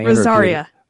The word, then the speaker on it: Rosaria: I'm pausing because I never can pronounce her name proper. Rosario. And when I Rosaria. – Oh, see, there you go.